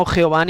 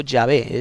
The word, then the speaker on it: Yahvé: la verdadera pronunciación en hebreo arcaico del Dios de Israel es Yahvé. Yahvé. No Jehová ni tampoco Yahvé, es Yahvé.